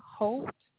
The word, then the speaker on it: Holt: It's Tyresha Holt.